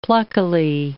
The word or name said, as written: pluckily